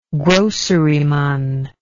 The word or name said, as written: groceryman